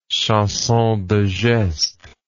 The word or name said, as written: chanson de geste